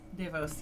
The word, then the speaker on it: Devos: And, you know, then the other main character of Carla was played by, hope I'm pronouncing this right, Emmanuelle Devos. – Devos, yes.